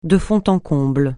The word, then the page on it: de fond en comble: francais